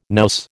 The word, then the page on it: nous: American English